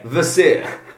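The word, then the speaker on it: Kvasir: Silent K. Vasir.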